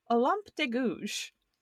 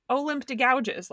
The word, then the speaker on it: Olympe de Gouges: So we're going to talk about our friend Olympe de Gouges, which is apparently how it's pronounced, not Olympe de Gouges, like it looks.